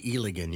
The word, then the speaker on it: Eligan: It's actually Eligan, you know. It's everybody pronounces it incorrect, but it's actually Eligan. How I remember is it rhymes with Gilligan.